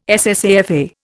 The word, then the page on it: SSAFA: Indian